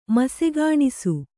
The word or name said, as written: ಮಸೆಗಾಣಿಸು